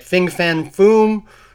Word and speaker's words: Fin Fang Foom: We get Fin Fang Foom.